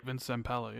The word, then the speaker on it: Zampella: How do you pronounce his name? Do you know? – I believe you said it right. Vince Zampella, yeah.